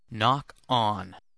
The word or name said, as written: knock on